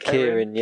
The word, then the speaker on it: Keirin: Like, just wacky events that you'd be like the Keirin. – Yeah. – Is that how you say it? – Keirin, yeah.